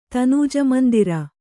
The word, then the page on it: ತನೂಜಮಂದಿರ: Kannada